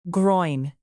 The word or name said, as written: groin